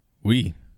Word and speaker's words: oui: And that's spelled O-U-I. Like, I'm reading a comic book. I'm actually reading The Boys comic book, and okay. Frenchie in there says that a lot. I'm like, yep, that's how it's spelled in French. – I took French in high school. Oui is about all I know, but it looks like it should say oi, like an old Jewish man, like oi, oi vey.